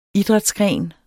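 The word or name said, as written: IDRÆTSGREN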